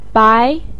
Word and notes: bai2: pinyin

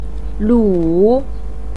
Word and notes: lu3: pinyin

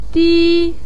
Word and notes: di1: pinyin

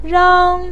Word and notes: rang1: pinyin

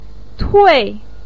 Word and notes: tui4: pinyin

